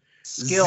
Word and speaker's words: SKLZ: Uh, notable purchases through the click through this week include the Skliz. Um, that's how I you would pronounce S K L Z. – Skills. Zip. – Oh, what? Skills.